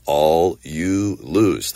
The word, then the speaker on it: allulose: It's spelled A-L-L-U-L-O-S-E. All you lose.